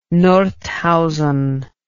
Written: Nordhausen

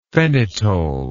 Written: phenetole

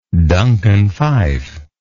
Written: duncan phyfe

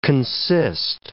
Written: consist

